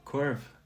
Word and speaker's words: korv: So actually, Arnaud told me about this, that you're a big fan of, how do you say that, Korv? – Korv, yeah. – Korv, yeah. Yes. Yeah, exactly, Le Saucisse, exactly. So, and so you had like this capsule collection for Post Details with like Korvs, and I think you did something with VSC as well before that, around that theme.